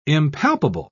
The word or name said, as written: impalpable